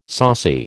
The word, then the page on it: saucy: American English